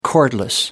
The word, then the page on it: cordless: American English